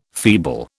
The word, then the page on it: feeble: British English